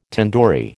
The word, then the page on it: tandoori: American English